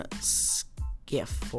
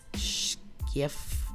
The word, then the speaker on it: Schiff: During Cohen's testimony before the House Oversight Committee, he omitted speaking with top Democrat Republicans Elijah, um, Elijah Cummings, Maryland, and Adam Skiff, or whatever. – Uh, how you pronounce his name? Sorry if I'm mispronouncing it. California. Before his appearance he told lawmakers he had spoken with Mr. Schiff, I think he's he pronounced it,